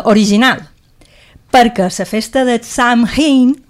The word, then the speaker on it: Samhain: Com se pronuncia? – Crec que es diu Samhain. – No se pronuncia. – No. – T'ho hauria d'escriure, però. – Samhain. – Exacte.